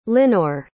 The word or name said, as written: linore